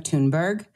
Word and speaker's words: Thunberg: By the way, she, I would like to take a moment here to compliment your pronunciation of Thunberg.